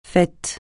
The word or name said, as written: fait